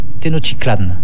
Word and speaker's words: Tenochtitlan: Tenochtitlan, je dis Tenochtitlan, je précise en nahuatl que le TL se prononce C. Tenochtitlan.